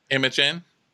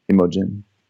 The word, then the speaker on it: Imogen: How do you say that name? – Imogen. – All the people coming onto the yacht. – It's like Imogen. – Imogen. – Like we have. – It's like a word.